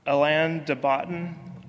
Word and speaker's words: Alain de Botton: There's a fairly young, popular philosopher right now named, I think you pronounce it, Alain de Botton.